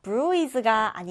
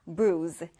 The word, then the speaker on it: bruise: bruise가 아니라 bruise. bruise 이렇게 발음합니다.